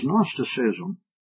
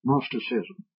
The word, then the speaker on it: Gnosticism: was Gnosticism. Gnosticism. Gnosticism. They don't pronounce the G.